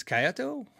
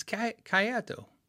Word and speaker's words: Kyoto: He says, I've been looking at a 62 reissue Telecaster from a seller in, I guess it's Kyoto? Why can't I say it? It's Kyoto. I'm saying it wrong.